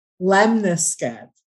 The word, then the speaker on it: lemniscate: How'd you say that again? – Lemniscate. Lemniscate. Or lemniscate, I've heard also.